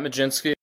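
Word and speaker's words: Mlodzinski: Yeah, so at Matt Majinski, it's spelled totally different than how it sounds.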